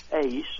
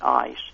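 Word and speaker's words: eis (εἰς): The Greek word that is used in that statement is eis, or some people pronounce it ice.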